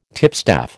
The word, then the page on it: tipstaff: American English